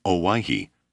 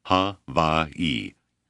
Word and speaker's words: Hawaii: The name is Hawaii. Ha-va-i. Accent on second syllable."